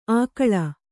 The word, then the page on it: ಆಕಳ: Kannada; ākaḷa